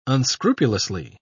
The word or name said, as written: unscrupulously